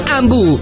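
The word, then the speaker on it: Hambu: Ambu è a Varese, Lecco, Meda e Vimercate. Ambu si scrive con l'H.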